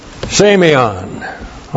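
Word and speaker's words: semeion (σημεῖον): S, long E, M, E, I, O, N. Semeion.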